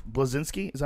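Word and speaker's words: Bleszinski: Cliff Blazinski? Am I saying it right? – Yep, fresh off the plane.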